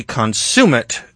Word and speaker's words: consummate: You are the consummate gentleman, and I say that with all deliberate emphasis on the second syllable.